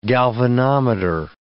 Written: galvanometer

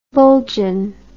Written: bullgine

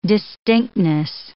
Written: distinctness

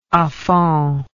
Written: afond

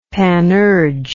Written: panurge

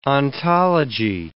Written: ontology

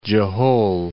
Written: Jehol

